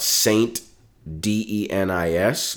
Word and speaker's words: Saint Denis: It's spelled out Saint D-E-N-I-S, but it's pronounced Saint Denis. – Oh, okay. – So it's more of a French pronunciation.